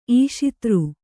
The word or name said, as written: ಈಶಿತೃ